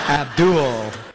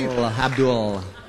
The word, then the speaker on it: Abdul: We gotta say it like that, man. It's creepy. Abdul. – That's how it's pronounced. – Holds on to the L a little too long.